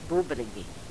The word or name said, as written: bubrigi